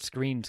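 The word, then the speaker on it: Skrine: I'm not 100% confident that Screen's going to be. And if I said the other, Scrine, I apologize. I'm still getting used to that one. It reads as Scrine, but it's pronounced as Screen.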